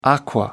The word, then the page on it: aqua: American English